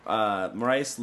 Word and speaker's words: Moraes: Moraes looked way better. Is that how you say his name? I don't. – Yep, yep.